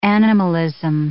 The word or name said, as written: animalism